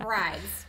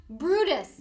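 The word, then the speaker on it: Bryde's: Okay, so I would say that's brides, right? Or brids. That would be weird. But brides is how that would be. No, Brutus. – Yep. It's pronounced Brutus whale. – Brutus whale. Makes zero sense.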